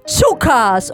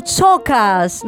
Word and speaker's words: chookas: Nel teatro australiano invece la formula augurale è l'espressione chukas o chokas, non so come si pronuncia. – Suka? – C-H-O-O-K-A-S. – Chukas. – Chukas.